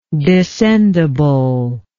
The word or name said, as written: descendibility